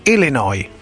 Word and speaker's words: Illinois: Ecco, ripetilo bene il primo, che l'Illinois non si legge la S finale, tutti sbagliano, invece si dice Illinois. – Illinois. – Non Illinois.